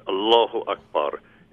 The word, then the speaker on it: Allahu Akbar: Allahu Akbar is pronounced. I've heard many distortions of that on television news in the last week. It's Allahu Akbar.